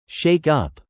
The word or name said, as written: shake-up